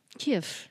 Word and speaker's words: Kiev: Can I just say, I am very impressed with your pronunciation of Kiev. – Kiev. – Because I've never been... Everyone else either goes, Kiev. – Chicken Kiev. – Like chicken Kiev.